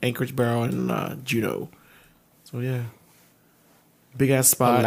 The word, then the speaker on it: Juneau: In an area of wilderness connecting the three city points of Juneau. – I looked it up. It's pronounced Juneau.